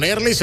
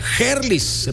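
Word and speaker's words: Herlis: O como le dicen por acá, Gerlis Rodríguez. Él ha dicho que se puede pronunciar de las dos maneras. Pero bueno, pues realmente en el castellano, pues la H es muda, ¿no?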